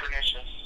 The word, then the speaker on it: Pernicious: That's the correct way to pronounce this, right? – Yeah, Pernicious.